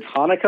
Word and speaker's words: Hanukkah: Is it Hanukkah or is it Hanukkah? – It's Hanukkah. Any way you want to say it.